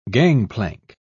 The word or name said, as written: gangplank